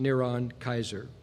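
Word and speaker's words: Neron Kaiser (נרון קסר): We know from ancient inscriptions that the Hebrew name for Nero Caesar was NRWNQSR, pronounced Neron Kaiser.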